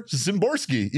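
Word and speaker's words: Szymborski: And the Z stands for Zimborski, even though his name starts with an S. It's weird. – Yeah, that does kind of bother me. But I mean, you clearly pronounce it with a Z sound.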